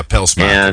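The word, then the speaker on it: Pelsmaekers: Well, he was irked about this not when I pronounced his name Pelsmachers. Yes. I didn't get that. – You got to get the S in there. Yeah, Pelsmachers.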